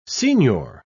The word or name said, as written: signor